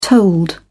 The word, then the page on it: tolled: British English